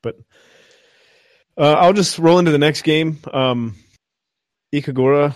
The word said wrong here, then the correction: Ikaruga: I'm probably butchering that, but it's... – Ikaruga.